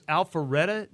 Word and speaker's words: Alpharetta: We have Cynthia on the line, and she's calling from Georgia, and I think it's Alpharetta, Georgia. Cynthia, did I say that right? – Yes, you did.